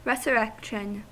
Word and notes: resurrection: American English

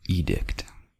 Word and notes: edict: American English

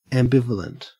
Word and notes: ambivalent: Australian English

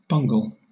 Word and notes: bungle: British English

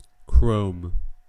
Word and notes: chrome: American English

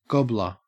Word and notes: gobbler: Australian English

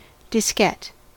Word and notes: diskette: American English

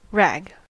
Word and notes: rag: American English

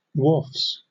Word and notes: wharfs: British English

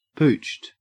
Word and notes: pooched: Australian English

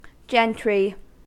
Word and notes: gentry: American English

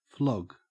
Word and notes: flog: Australian English

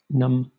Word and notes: numb: British English

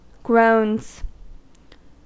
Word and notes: groans: American English

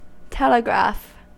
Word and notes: telegraph: American English

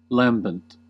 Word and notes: lambent: American English

